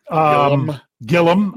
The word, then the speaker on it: Gilliam: Russ says, Joe Gilliam. Gilliam. I was putting the extra. – Yeah, don't give it the extra syllable.